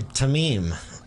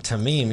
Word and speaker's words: Tamim: Tamim. – Tamim? – Tamim, you got it.